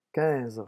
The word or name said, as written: quinze